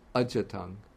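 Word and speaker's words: ajjhattaṃ: But in Pali, we don't have this combination of DH and Y, so DHY turns into ja, which is easier to pronounce. So it becomes ajatang.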